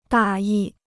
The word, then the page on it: 大意: dà yi